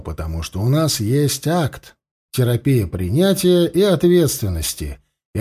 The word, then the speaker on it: ACT: Мы официально произносим акт как одно слово – акт, а не как аббревиатуру ACT.